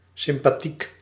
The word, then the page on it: friendly: American English; /ˈfrɛndli/